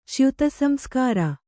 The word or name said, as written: ಚ್ಯುತಸಂಸ್ಕಾರ